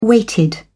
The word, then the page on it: weighted: British English